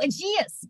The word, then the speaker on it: Aegeus: Oh, Aegeus.